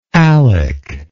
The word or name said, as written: alec